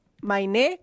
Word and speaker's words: Mainé: Mainé, con Mainé. Acento, okay.